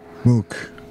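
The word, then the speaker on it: MUCC: How do you say this again? Muku? – Mook.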